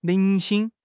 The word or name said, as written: 零星